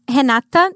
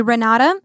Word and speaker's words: Renata: So I was using, this is like a recent rebranding that I did with my name, because my name is Renata, that's how I pronounce in Portuguese, but in English would be Renata Vontobel.